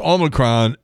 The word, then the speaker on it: Omicron: You know, yesterday, I think I mispronounced the damn thing all the entire podcast, but it's Omicron, and at least get the booster.